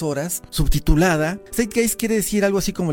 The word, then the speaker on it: Zeitgeist: La primera película de la que quiero platicar es Zeitgeist, pero se escribe Z-E-I-T-G-E-I-S-T.